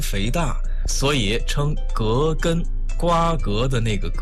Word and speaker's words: 葛根: 说出来您肯定会很惊讶，这个中成药啊，只有一个成分，就是葛根。说到葛根这个中药，我们再来说一下它的读音。我们身边有很多朋友都把葛根读作葛根，姓葛的那个葛。